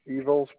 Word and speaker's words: Ivo: Okay, first name is Ivo, spelled like I like international, V like victory, O like ocean.